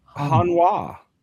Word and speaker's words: Hanwha: Hanwha. – All right, you know, kind of like Sunwha. You might have a Sunwha down the street from you.